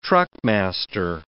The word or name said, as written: truckmaster